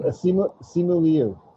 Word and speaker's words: Simu Liu: Simu Liu.